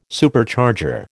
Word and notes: Supercharger: American English

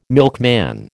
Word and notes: milkman: American English

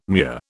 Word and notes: mure: British English

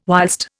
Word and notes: whilst: Indian

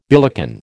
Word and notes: billycan: American English

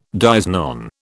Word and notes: dies non: British English

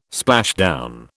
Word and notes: splashdown: British English

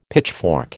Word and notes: pitchfork: American English

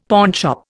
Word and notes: pawnshop: Indian

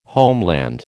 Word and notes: homeland: American English

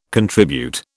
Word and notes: contribute: British English

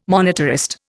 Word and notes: monetarist: Indian

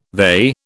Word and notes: they: British English

